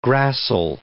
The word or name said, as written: gracile